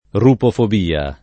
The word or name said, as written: rupofobia